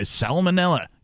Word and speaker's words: salmonella: However you 46 year old Tyrone has sued Frankfurt Christmas Market Limited over a food infection caused by salmonella. Yes, I pronounced the L in salmonella that has seen him suffer for over five years.